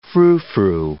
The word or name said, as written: froufrou